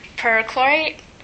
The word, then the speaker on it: perchlorate: Someone mentioned perchlorate.